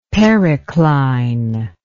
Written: pericline

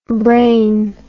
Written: braine

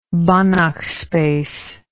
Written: Banach space

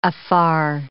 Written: afar

